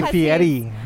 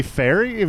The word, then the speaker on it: Fieri: With it, it's actually Fieri. – Yeah, because he's a legitimate Italian chef. So do you say fairy?